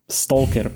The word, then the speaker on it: Stalker: Mm, Stalker. Stalker, pardon. Prepačte všetci, čo nenávidíte moju výslovnosť.